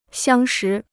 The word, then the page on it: 相识: xiāng shí